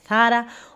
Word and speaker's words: Zara: Το 1975 λοιπόν ο Αμάνσιο Θάρα, ναι όλοι τα Ζάρα τα κάνουμε pronounce λάθος γιατί ο άνθρωπος είναι Ισπανός και δεν τον λένε Ζάρα, τον λένε Θάρα.